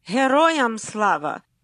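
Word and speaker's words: Heroyam Slava (Героям слава): And the answer is Heroyam Slava.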